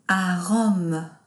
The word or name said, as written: a Rome